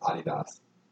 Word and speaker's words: Adidas: How is Adidas supposed to be pronounced? – Adidas. Fun fact.